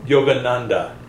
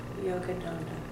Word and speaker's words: Yogananda: Yogananda. – Yogananda.